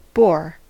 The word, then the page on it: boar: American English